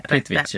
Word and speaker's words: Plitvice: Sen då, om man nu ska åka och titta på saker som vi tycker man ska göra, så är det definitivt Plitvice, jag vet inte om jag uttalar det Plitvice. – Rätt.